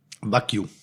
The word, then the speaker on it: Wagyu: Wagyu. – Wagyu? – Wagyu. – Ah, okay, da habe ich mich immer gefragt. Ich habe oder nenn es einfach japanisches Rind. – Okay, Wagyu.